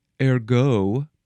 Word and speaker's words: ergot: The name is a French word. They would probably pronounce it ergo.